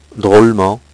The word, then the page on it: drolement: French